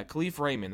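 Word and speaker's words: Kalif Raymond: Yeah, Khalif Raymond.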